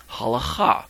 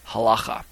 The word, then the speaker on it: halakha: But before we go further into that direction, what we're going to talk about is this topic known as halacha, or some of you have heard it pronounced halacha. Pastor Mark McClellan is fond of calling it halacha. I simply just say halacha.